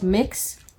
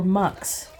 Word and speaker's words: Mx: The most popular alternative to ma'am and sir for non-binary people is MX, pronounced mix or mux.